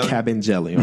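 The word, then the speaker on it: Kabengele: And that's the first time I've ever heard his name pronounced. – Cool, Capengelly? – Capengelly. Okay. Yeah. I'll remember that.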